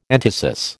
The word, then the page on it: entasis: American English